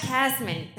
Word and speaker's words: Casteman: Baccarat Bonanza, Ash Casterman. Did I say it right that time? – Yay! – Ash Casteman. Imagine I had that right the whole time.